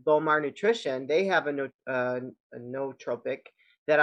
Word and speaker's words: nootropic: But I do take a nootropic, or how'd you pronounce it? – Nootropic.